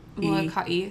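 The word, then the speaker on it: Molokai (Molokaʻi): And I was told by the internet that in the Hawaiian language, that means you're supposed to put a natural pause there. So Molokai. – So you were probably saying it right according to Hawaiian grammatical rules.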